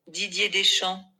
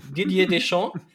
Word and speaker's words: Didier Deschamps: Und weil ich seinen Namen nicht aussprechen kann, habe ich gegoogelt, wie man ihn ausspricht und bin auf folgendes Ergebnis gestoßen. Hört mal. Didier Deschamps. Didier Deschamps. ähm, hier ausgesprochen von der Userin Fohl wie auch immer,